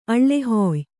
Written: ಅಳ್ಳೆಹೊಯ್

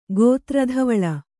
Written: ಗೋತ್ರಧವಳ

